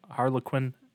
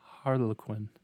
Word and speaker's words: Harlequin: Harlequin. I always say it wrong because of Harley Quinn from DC. – Oh. – Harlequin.